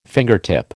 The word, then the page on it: fingertip: American English